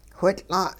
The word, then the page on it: whitL'a': hupa